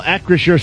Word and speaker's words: Acrisure: Acrisure Stadium.